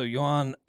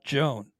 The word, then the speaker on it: Yoan: It is Yoan Adon, so that's good to know. Not Joan. Joan wouldn't make a lot of sense anyway.